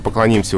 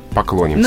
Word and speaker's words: поклонимся: От окончания оно уже оторвалось, и варианты «поклониться», «поклонимся» уже не допускаются. «Поклонишься», «поклониться», «поклонимся». – Ну, то есть запоминаем так. Выходим на поклон, соответственно, да? – Да. – Вот там же и стоит это ударение.